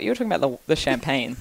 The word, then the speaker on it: champagne: Oh, how do you say it, Josie? You said you'd tell us. – Oh, champagne. – Champagne. Okay. – It is. I know you told me I sounded Italian, but that's how French Julian said it.